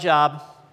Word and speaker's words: Job: It's spelled like Job, but it's just an inside thing. We all know we're supposed to say Job.